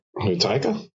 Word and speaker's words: Taika: Taika.